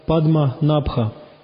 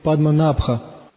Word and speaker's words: Падманабха: Одиннадцатая мантра – Падма Набха. Падма Набха. Ударение в слове Падма Набха. Набха. Вот это А. В слове Набха. Это туда, а это долгое А. Ставит туда ударение.